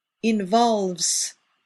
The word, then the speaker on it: involves: Be sure to pronounce the S at the end of the third person singular. Involves.